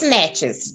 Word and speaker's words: Natchez: It's Miss Natchez, just like the rubber boot.